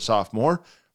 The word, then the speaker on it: sophomore: You see it right there in the word, sophomore. The O is still there, sopho. We just pronounce it sophomore.